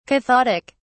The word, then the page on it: cathodic: British English